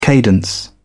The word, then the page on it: cadence: British English